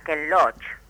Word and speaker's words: Ken Loach: es el cineasta Ken Loach.